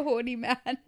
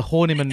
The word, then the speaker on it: Horniman: It just sounds like it's called the Horny Man Museum. – Well, it is. It can be pronounced that way if you're an idiot. Yeah, you can call it the Horny Man Museum, as you two both have before we started recording this podcast. It's the Horniman Museum.